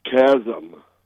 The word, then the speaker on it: CSM: And this acronym given by the bishop is CSM, and I pronounce it chasm.